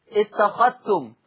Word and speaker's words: اتخذتم: طيب الفائدة الأخرى، أضغم ورش الذال الساكنة في التاء المتحركة، أضغم ورش الذال الساكنة في التاء المتحركة في لفظ اتخذتم، لا يتلفظ بالذال، اتخذتم، أخذتم، أخذتم، أخذتوا، أخذتوا، إلى آخره ونحو هذه الألفاظ من جملة الاتخاذ.